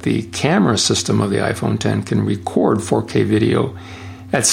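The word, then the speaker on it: iPhone X: Roman numeral X, pronounced iPhone 10, with facial recognition called Face ID, with an infrared camera.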